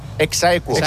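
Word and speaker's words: ex aequo: ¿Cómo se dice? – Exaequo. – Así se dice.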